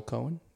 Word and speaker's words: Coen: Joel Cohen, if I'm saying that right. C-O-E-N? – Yeah.